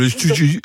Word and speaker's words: jiu-jitsu: Alors, animation de jiu-jitsu, j'espère que je le dis bien. – Oui, c'était parfait, le jiu-jitsu.